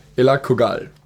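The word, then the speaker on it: Elagabal: Elakogal. Entschuldigung, ich hatte es gerade falsch pronunciated. Elakogal.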